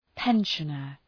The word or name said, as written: pensioner